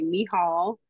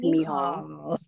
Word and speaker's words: Michal: Okay, my full name is, I'm going to say the actual pronunciation of it when I say it. So it's actually Michal Nichelle Williams Johnson. So that is how you say my first name is Michal.